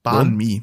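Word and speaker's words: Bánh mì: Ja, Ban Mi. Ne? – Ban Mi. Wie schreibt man's? Ban, Ban Mi. B-A-N-H-M-I, glaube ich.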